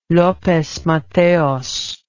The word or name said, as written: lopez mateos